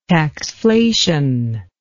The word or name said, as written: taxflation